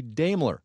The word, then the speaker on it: Daimler: We got a complaint or two about the pronunciation, saying it ought to be Daimler. So we checked.